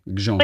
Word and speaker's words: Gjon: Aber glaubt Also da ist ja kein, das G ist G-J-O-N, ne? Ja, G-J-O-N, Gijon.